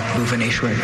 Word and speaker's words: Bhubaneshwar: The start of that second syllable. – Bhuvaneshwar, B-H-U-B-A-N-E-S-H-W-A-R, Bhuvaneshwar. – That is correct.